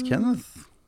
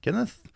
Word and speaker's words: Kenneth: But the H becomes apparent when you say it in, pronounce it in English. – The H. – Yeah, the silent H. It suddenly just appears. Kenneth. I'm here too. – Yeah. – Yeah, I mean, because you don't really hear it in Norwegian.